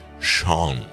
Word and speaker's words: Sean: Sean, se pronuncia Sean, como Sean Connery. – Clase número uno. – No, pero está bueno que lo decís. ¿Viste cuando aparece Sean Penn, la gente empieza Sin Penn, Sean Penn? – Claro, pues se escribe Sean.